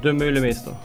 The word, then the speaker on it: Demeulemeester: Demeulemeester.